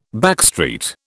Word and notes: backstreet: British English

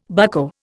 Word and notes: bucko: Indian

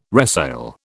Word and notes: resale: British English

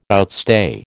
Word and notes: outstay: American English

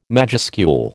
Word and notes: majuscule: American English

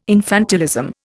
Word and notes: infantilism: Indian

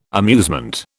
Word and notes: amusement: British English

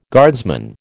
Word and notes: guardsman: American English

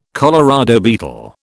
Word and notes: Colorado beetle: British English

